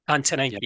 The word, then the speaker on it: Antonine: I saw how many people you had at the, how do you say it, is it the Antonine? – Antonine, yeah.